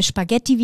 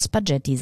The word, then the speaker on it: Spaghetti: Und deswegen, also vielleicht kann man sich damit so ein bisschen merken, wenn hinter G und C ein H ist, dann spricht man es eigentlich wie ein K. – Man muss es auf Deutsch immer genau andersrum aussprechen. – Spaghetti.